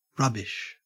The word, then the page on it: rubbish: Australian English